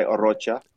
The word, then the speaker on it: Arocha: Most people say Arocha. My family goes by Arocha.